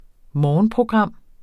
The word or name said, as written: morgenprogram